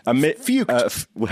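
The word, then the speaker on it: Feucht: Sean Fucked, F-E-U-C-H-T. I guess that's how you say it. – Fuked.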